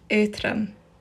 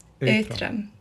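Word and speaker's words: Aotrom: Redox dragon is called Uthram. Uthram. And this means light.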